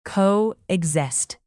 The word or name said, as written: coexist